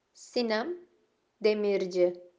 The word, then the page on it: Sinem Demirci: female